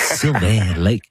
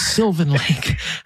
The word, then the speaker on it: Sylvan Lake: She said, "You're saying it wrong. It's not Sylvain Lake. It's Sylvan Lake."